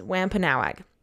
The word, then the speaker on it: Wampanoag: The American pronunciation is Wampanoag, okay?